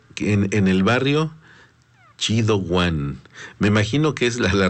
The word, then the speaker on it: one: Y nos dice: aquí suena Juan, pero es One. Nos escribe O-N-E, como se escribe en inglés, se pronuncia Juan en inglés, perfecto.